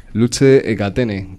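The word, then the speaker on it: Luce e catene: Muy bien, pues vamos a escuchar cómo suena Luce Ecatene. ¿Cómo se pronuncia? Que mi italiano es pésimo. – Lo has dicho muy bien. Perfecto. Luce Ecatene.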